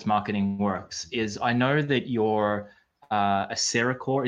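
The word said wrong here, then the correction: Cercacor: Is that how you say it? – CercaCore.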